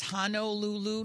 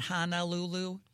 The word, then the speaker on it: Honolulu: It's Honolulu, not Honolulu. For Hawaiians, it would be like, oh, all right. It would be like referring to St. Louis as St. Louis.